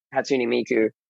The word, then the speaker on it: Hatsune Miku: Like I think right now people have some barriers of that, but I think it's very quickly changing because of people like Hatsune Miku. I think I might have said that wrong. – No, that's the right way to say it. That's the Japanese.